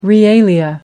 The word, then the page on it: realia: American English